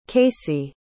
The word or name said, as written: caycee